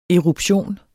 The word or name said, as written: ERUPTIONEN